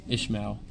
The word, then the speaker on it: Ishmael: Ishmael.